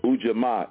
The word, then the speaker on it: Ujamaa: And that's spelled U-J-A-M-A-A. Again, U-J-A-M-A-A, Ujamaa.